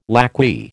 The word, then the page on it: lacquey: American English